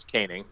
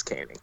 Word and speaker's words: Koenig: So another guy that Brown brought with him from Troy's defensive coordinator, Vic Koenig. Did I say that right? – I believe it's Koenig. Yeah, I think it's Koenig. I would go 98% Koenig.